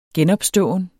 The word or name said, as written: genopståen